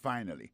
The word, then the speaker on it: finally: Finally, c'est F-I-N-A-L-L-Y. Finally. T'as des prononciations, oui? Finally. Accent tombé sur le premier syllabe là. Finally. F-I-N-A-L-L-Y.